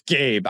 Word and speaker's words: Gabe: It's Gabe. I didn't know how to phonetically spell Gabe, so it's Gabe.